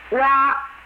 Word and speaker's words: 蛙: 之 is spelled Z-H-I, and is equivalent to the English preposition of. And Wa, W-A, is the Chinese for frog. 井底之蛙, the frog at the bottom of the well. It is written in the ancient book, Zhuangzi, that there was once a frog who had been living in a well from the day he was born.